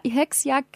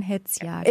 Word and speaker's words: Hetzjagd: Und nicht Hexer, Hexjagd gesagt, sondern Hetzjagd.